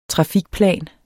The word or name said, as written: trafikplan